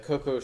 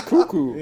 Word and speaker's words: Cuckoo: He is telepathically talking to the one of the Coco sisters, or Cuckoo sisters that he was dating. – I like Coco. – Yeah. It's Cuckoo.